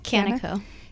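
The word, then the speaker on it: Kaneh Co: Yeah, Canna Co.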